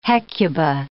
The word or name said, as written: Hecuba